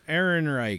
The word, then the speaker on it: Ehrenreich: Alden Einreich. – But you saw me reaching for it. – It's kind of gross. Einreich or whatever. – Alden Ehrenreich.